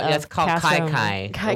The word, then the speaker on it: kiki: It's called Kai Kai when you do that. – What's it called? Kai Kai when you like Kiki, but pronounced Kai Kai.